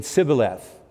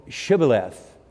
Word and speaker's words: Shibboleth: But the pronunciation, they couldn't pronounce the SHs, so they said Sibboleth. No, it's Shibboleth.